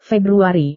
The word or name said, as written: februari